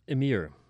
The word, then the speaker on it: Ymr: Ymir. – Okay, it's Y-M-R. – Y-M-R.